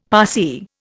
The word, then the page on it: Parsee: Indian